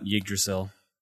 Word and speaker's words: Yggdrasil: Yggdrasil.